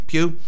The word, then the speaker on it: Pugh: Pugh. – Pugh for Little Women.